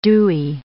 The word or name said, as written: dewy